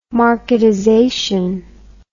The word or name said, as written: marketization